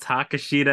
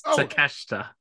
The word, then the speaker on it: Takeshita: It's not Takeshita at all. It's Takeshita.